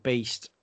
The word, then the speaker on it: Baest: The band are called Beast. Like I said, it's spelt BAEST if you're looking for it.